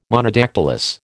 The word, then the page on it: Monodactylous: American English